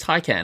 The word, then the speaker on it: Taycan: Taycan. Is that how you say it? – Taycan, yeah. Taycan? – No, I think it's Taycan.